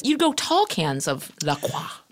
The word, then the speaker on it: La Croix: Those assholes, if you go on their site, they say it's pronounced La Croix. – Sure. – Well, spell it C-R-O-Y then.